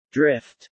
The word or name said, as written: drift